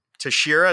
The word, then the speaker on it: Teixeira: Like, it's either gonna be Teixeira, is that how you say it? – Yep.